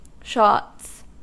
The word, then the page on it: shots: American English